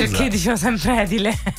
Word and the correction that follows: edile: L'accento cade sulla I e non sulla E, non è edile, ma è edile.